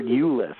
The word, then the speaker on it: Ulis: Now, how big is this Tyler Ulis? Am I saying that name right? – Yeah, you're saying it right.